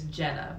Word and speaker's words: Ginnah: Her name is Jenna, but it's spelled G-I-N-N-A-H.